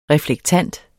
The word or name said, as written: reflektant